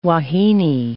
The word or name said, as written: wahine